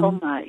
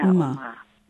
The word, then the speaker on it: Umma: U-M-M? – Umma, yeah, Umma.